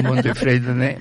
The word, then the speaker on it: Montefredane: Infatti non avevo parlato con lei, però io quasi voleva dire Montefredane, va bene? – No, è Montefredene, ma Montefredane è praticamente così, l'accento lo si mette da tante persone nella città.